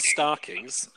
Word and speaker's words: Starkings: And my family name is Starkings, and I'm from Yorkshire, so I am basically Stark. – But isn't that the old English pronunciation of stockings? – No, it's definitely Stark.